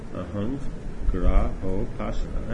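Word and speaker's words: Ahangrahopasana: But we just don't do this Ahangrahapasana. Ahangrahapasana. Actually, I said it right.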